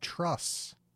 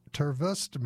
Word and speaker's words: TRVSS: that band is from Pittsburgh, and they're called Truss, but it's spelled T-R-V-S-S in all caps. But trust me, or Tervust me, they're called Truss. I asked, because I wanted to know how to say it on the radio.